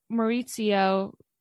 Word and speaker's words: Maurizio: He was Maurizio, which I'm hoping I say that properly. – That's right.